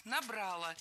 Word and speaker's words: набрало: Первый – «набрало». Ударение поставлено неверно. Правильный вариант – «набрало». Дело в том, что большинство, можно даже сказать, все проблемные глаголы в прошедшем времени в среднем роде имеют безударное окончание.